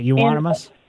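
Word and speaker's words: Euonymus: I have a couple Euronymus, I think that's how you pronounce it, bushes. – Right, Euronymus.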